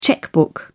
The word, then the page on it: chequebook: British English